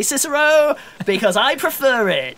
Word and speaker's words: Cicero: Cicero. Cicero. Ah! Who's asking? – I think it's pronounced Kikero, actually, but you know.